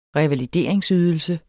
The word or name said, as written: revalideringsydelse